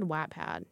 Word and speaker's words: Wattpad: He was like, Wattpad? I've never pronounced it like Watt. Like, I guess it is W-A-T-T. I've always said Wattpad. – I've pronounced it like Watt, like Noah, Wattpad. – Wattpad? – Yeah. – Watt? – Yeah. – Like, unit of measurement.